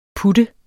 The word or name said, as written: putte